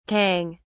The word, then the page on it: cong: British English